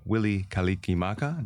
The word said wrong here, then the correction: Mele Kalikimaka: Did I say that right? – Well, I don't know. I'm not Hawaiian, but, okay, but I know Mele Kalikimaka, but yeah.